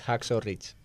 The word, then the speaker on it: Hacksaw Ridge: Tras el éxito que tuvo con Hawkwood Wright... – ¿Alguien lo puede pronunciar mejor? – Sí, por favor. ¿Cómo? – Es Hacksaw Ridge.